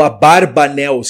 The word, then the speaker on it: Abarbanel: Ou Abarbanel, segundo alguns pronunciam.